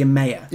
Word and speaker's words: Emeya: how do we say it again? – Yes, yeah. The Emeya.